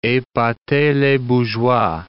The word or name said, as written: epater les bourgeois